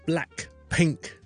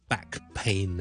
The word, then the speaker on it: Blackpink: 咁好多人都讲佢哋啦，咁今日呢都同大家讲一讲佢哋个名字嘅发音，因为好多人呢都攞 black pink 即黑色粉红咁样啦，同埋腰背痛呢 back pain 呢就攞嚟开玩笑。咁其实呢两组字嘅发音有咩分别呢？ back pain 呢 back pain back pain 当然你冇咗个 L 音啦， black 同 back 啦， black 同 back 嗰个分别，先读咗个 L 音，读完个 lack 呢跟住先读个 black，咁咧就会清楚啲啦。